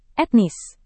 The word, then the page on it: etnis: indonesian